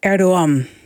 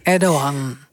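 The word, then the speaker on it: Erdoğan: Nou, eigenlijk precies ertussenin. Die G die spreek je eigenlijk niet uit. Dat zijn G met een dakje erop. Dus het is Erdogan. – Erdogan.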